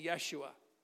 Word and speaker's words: Yeshua: If you were to say the name of Jesus in Hebrew, you would say Yeshua. It's the exact pronunciation.